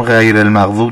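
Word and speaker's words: غير المغضوب: حرف غین رو یه بار دیگه دقت بکنید، در همون غیر المغضوب دوباره تکرار کنید. – غیر المغضوب. – خب ببین، حرف غین باید مثل حالت غرغره کردن به این شکل تلفظ بشه.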